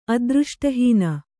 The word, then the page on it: ಅದೃಷ್ಟಹೀನೆ: adřṣṭahīne